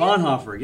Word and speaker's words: Bonhoeffer: But the book he wants to discuss is Bonhoeffer, is that how you say it? – Bonhoeffer, yep.